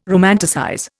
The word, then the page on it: romanticize: Indian